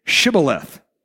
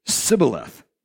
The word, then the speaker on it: Shibboleth: Then you should say to him, then say Shibboleth. And he would say Sibboleth, for he cannot pronounce it right.